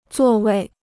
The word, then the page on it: 座位: zuò wèi